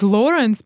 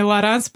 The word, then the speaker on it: Лоранс: Я постоянно хочу сказать Лоренс, потому mm-hmm. что звучит для меня правильнее, но mm-hmm. правильнее Лоранс, потому что ударение на последнем, то, что французский, вот это вот все.